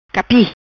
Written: Capi

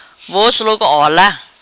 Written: Vos Logo Ola

